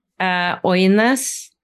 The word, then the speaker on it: Ønæs: Ønæs.